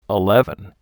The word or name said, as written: Eleven